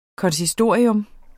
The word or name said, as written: konsistorium